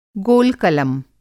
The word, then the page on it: गोलकलम: Hindi